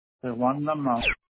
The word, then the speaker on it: Dalmau: o el candidato del PIP y MBC Juan Dalmú, o el candidato del Proyecto Dignidad Javier Jiménez Pérez? – Dalmau, no lo pronuncio bien. – ¿Dalmau? ¿Juan Dalmau? – Sí, Juan Dalmau.